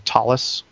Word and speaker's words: Ptolus: It's called Tolus, which is spelled P-T-O-L-U-S.